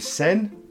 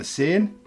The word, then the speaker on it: Seine: Question 3, the second longest river that flows through France is the River Seine or the Seine. I think it's pronounced Seine.